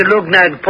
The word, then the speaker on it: Luggnagg: He arrived up the river Clumegnig, right outside the town of Lugnag. Oh, I'll spell that for you. L-U-G-G-N-A-G-G.